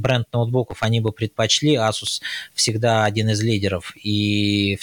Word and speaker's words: Asus: Правильно говорить Asus, э, ну, так э, в английском произношении должно говорить. Но ты знаешь, э, давай уже всем расскажем, что тайваньцы сами на протяжении там больше чем 20 лет говорили Asus, и никого это никогда не смущало, но потом все все-таки решили решили, что давайте будем говорить правильно и давайте будем стремиться говорить Asus.